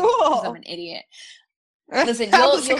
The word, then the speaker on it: LYNQ: The next one I have is called Link, and it's spelled really cute. It's LYNQ.